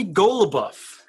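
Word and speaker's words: Goloubef: Cody Golubov is how you pronounce it on Elite Prospects.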